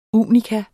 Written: unika

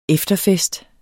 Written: Efterfest

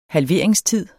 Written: halveringstid

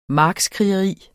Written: markskrigeri

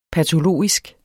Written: patologisk

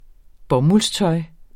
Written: bomuldstøj

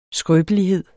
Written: skrøbelighed

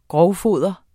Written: grovfoder

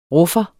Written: ruffer

